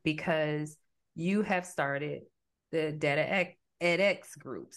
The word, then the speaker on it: DataEdX: So I'm always messing that word up. I want you to say it the way you pronounce it. So the company that you started is Data edX group, and it is data education with the X factor.